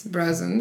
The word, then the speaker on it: Brousins: B-R-O-U-S-I-N-S. Brezens.